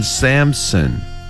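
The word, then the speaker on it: Samson: 마노아 마노아를 마노아 이렇게 발음하시면 되고 그 다음에 삼손은 샘슨 샘슨 한번 더요.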